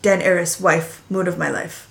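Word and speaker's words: Daenerys: But in the book, it literally says Dan, A-R-E-S, Den Eris. – Den Eris.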